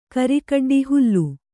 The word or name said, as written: ಹಲಿ